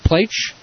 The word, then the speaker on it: Plaich: His name is Steve Plaich, rhymes with H.